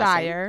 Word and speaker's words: Dyer: And you went, "Joseph Quinn, Charlie Heaton, and Natalia, how do you say her last Dyer.